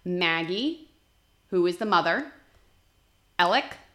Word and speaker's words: Alec: it is not alex murdaugh the name, the, the way the name is spelled is M U R D A U G H, and the main guy, his name is Alex, A L E X, but that's not how you pronounce it. It is Alec or Alec Murdaugh.